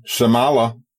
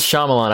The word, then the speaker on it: Shyamalan: It is Shyamalan. I've been saying it wrong. I always pronounce the little Y in it, the Shyamalan. I always just put that little thing, but no, it's Shyamalan.